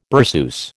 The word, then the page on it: berceuse: American English